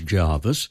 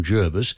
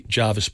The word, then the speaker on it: Jervis: The English pronunciation is Jarvis. Mm. When the Navy set up a major base there, they asked for the pronunciation to become Jervis because I gather that it was named after a British admiral and he pronounced his name Jervis, which the Navy knew, and therefore they wanted his pronunciation to apply. Mm. But Jarvis is more common for that name in England.